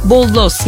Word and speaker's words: bollos: Boyoz kelimesi İspanyolca'da bollos yani küçük somunlar anlamına gelir. İspanyolca'da iki L harfi Y sesiyle okunduğu için boyoz olarak telaffuz edilir.